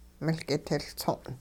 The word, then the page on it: miL-kitiLtso'n: hupa